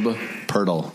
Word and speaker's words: Poeltl: Jakob Poeltl.